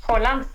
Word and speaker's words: Haaland: Haaland. – Yes. – Haaland. – Olha, então acho melhor a gente ficar no Haaland pra não dar problema, viu? A quinta série aflora dentro da gente. Vamos de Haaland amanhã, tá bom? – Beleza.